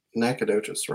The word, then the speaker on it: Nacogdoches: And then last but not least is the Southeast Texas Kayak Bass League on Lake Nacogdoches. I'm going to say that I said that right. 87 anglers. – Yeah, that's Nacogdoches, right.